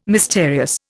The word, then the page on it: mysterious: Indian